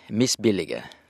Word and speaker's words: misbillige: For det første misbillige. Så enkelt og greit er det. Vi må ta det som et skikkelig norsk ord.